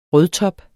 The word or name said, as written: rødtop